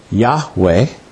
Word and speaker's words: Yahweh: We pronounce that Yahweh. We're not sure exactly how it's pronounced because when the Bible was written originally, they had no vowels. But we think it's Yahweh.